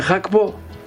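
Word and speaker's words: Gakpo: Επειδή έμενε ένα διάστημα στην Ολλανδία, το G προσφέρεται who και όχι γκου, όπως τα αγγλικά. Όπως και το τυρί γκούντα. Είναι χάουντα. Υπάρχει άλλος και μονίμη πολύ. Ευχαριστώ, διότι επιβεβαιώνεις αυτό που είπα, ότι το γκου προφέρεται who. Έτσι δεν είναι. – Ναι. – Αφού το λένε Χάκπο.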